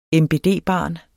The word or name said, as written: MBD-barn